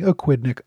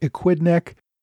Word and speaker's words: Aquidneck: This week, I went over the raids that British General Robert Pigot ordered against the rebel forces surrounding Aquidneck Island. And I've been told numerous times that I've been mispronouncing Aquidneck, Equidneck Island.